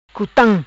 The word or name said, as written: Cutam